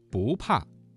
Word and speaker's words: 不怕: 好，不怕这个词当中的不字在四声前要变成二声，不怕。